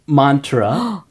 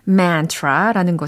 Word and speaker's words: mantra: 그 다음에 맨 마지막에 mantra라는 것을 들으셨어요. 특히 명상을 많이 하시는 분들은 이 단어가 좀 익숙하실 것 같아요. – 아, 제가 mantra라고 했죠? 예. 한글로 발음했네요.